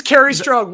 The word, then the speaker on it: Kerry Strug: I'm hoping to stick the landing, and I want to say, is that the Kerry Strug call? – That is Kerry Strug.